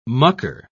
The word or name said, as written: mucker